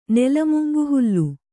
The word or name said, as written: ನೆಲಮುಂಗುಹುಲ್ಲು